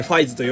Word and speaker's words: 555: が、えーと、 555 回、最高に好きな仮面ライダーファイズの主人公、乾匠の話をします。という回ですね。えー、 10 月 5 日の回でございます。これは、まあ、ファイズといえばやっぱり 555 なんですよ。 555 と書いてファイズと読むんですけど、 555 回に絶対やろうと思っていた企画でございまして、えー、もう大好きなんですよ。